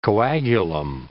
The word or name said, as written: coagulum